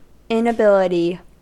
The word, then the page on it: inability: American English